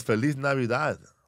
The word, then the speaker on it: Feliz Navidad: Yes, Feliz Navidad. That's how you say it.